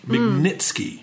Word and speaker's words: Magnitsky: who was a Russian attorney by the name of Sergei Magnitsky. Magnitsky.